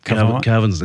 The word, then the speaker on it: Cavan: Cavan's the county, C-A-V-A, and Cavan.